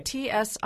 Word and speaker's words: Tsiolkas: It's Christos Tsiolakis. – Okay. – Tsiolkas.